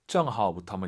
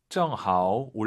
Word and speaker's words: 正好: 정하오부터 한번 읽어볼게요, 선생님. 다시 한번. – 正好出去运动一下。 – 정하오 올라가면 안 됩니다. 뒤에가 일성이니까 반삼성 정하오. 정하오 出去 이런 게 포인트예요.